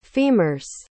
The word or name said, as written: femur's